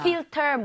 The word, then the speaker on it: filter: F-I-L. T-E-R. – Mm-hmm. – 자, 근데 발음 주의하셔야 되는 게 지금 fill 할 때 L 있죠? – 네. – 그리고 ter 할 때 R. – 와. – Right? L-R. 이 순서로 되어 있기 때문에 네. – 혀하고 입모양이 제대로 들어가야 돼요.